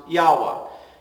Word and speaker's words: Yahweh: in Hebrew, pronounced Yahweh.